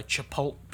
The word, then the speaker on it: Chipotle: I don't know what it is about people who go to Chipotle, yep. – But I think I'm the only normal person that actually goes. Am I saying that right, by the way? Chipotle? – Chipotle.